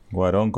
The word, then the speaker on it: Cuarón: Cuarón. con acento en la O.